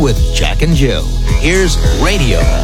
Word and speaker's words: Raydio: The second is recorded by a four-man group from Los Angeles called Radio. That's spelled R-A-Y-D-I-O.